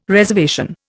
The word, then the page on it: reservation: Indian